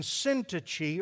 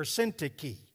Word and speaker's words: Syntyche: I use that because that's what happened with Euodia and Syntyche or Syntyche. You can pronounce it either way.